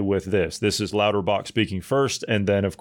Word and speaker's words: Lauterbach: Carl Lauterbach is his Lauterbach.